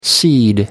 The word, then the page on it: cede: American English